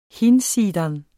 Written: hinsidan

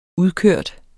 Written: udkørt

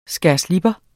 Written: skærslipper

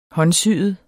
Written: håndsyet

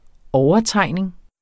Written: overtegning

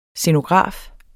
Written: scenograf

